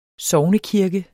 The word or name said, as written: SOGNEKIRKE